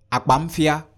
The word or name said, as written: akpamfia